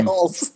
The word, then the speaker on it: Hulce: Murray Abraham and Tom Hulce is how I understand Hulce, that is pronounced.